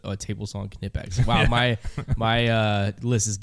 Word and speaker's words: Knipex: And just let everybody at home know it's spelled Knipex if you're trying to look it up, and it's pronounced Knipex.